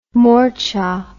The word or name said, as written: morcha